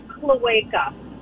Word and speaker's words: cloaca: It's actually pronounced cloaca. – Cloaca. – Cloaca. – Like a wakeboard.